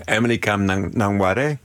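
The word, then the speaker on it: Emily Kame Kngwarreye: Je moet me even helpen met de uitspraak. Emily Kame Knwari. – Emily Kame Nangware. – Wie is zij? – Emily Kame Nangware is een van de grootste beeldend kunstenaars van de 20e eeuw.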